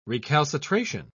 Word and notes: recalcitration: British English

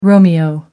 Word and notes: romeo: American English

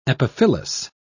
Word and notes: epiphyllous: British English

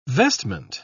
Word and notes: vestment: British English

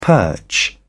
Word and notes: perch: British English

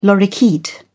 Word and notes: lorikeet: British English

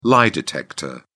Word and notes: lie-detector: British English

